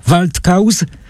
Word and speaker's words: Waldkauz: Serdecznie panu dziękuję za tę rozmowę, zapraszając jednocześnie czytelników do lektury nowej książki zapowiedzianej przez nas Waldkauz, pisze się przez Z, czyta się przez C, bo to z niemiecka, Puszczyk, wydanie wydawnictwa Marginesy już w październiku, a u nas teraz rozmowa ze Szczepanem Twardochem.